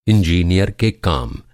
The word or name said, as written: इंजीनियरिंग